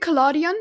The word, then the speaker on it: collodion: How is it supposed to be pronounced? – I think collodion, but it's written in Dutch. I do not know if in English it's the same word. I think so. It should be.